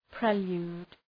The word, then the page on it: prelude: ‘prelju:d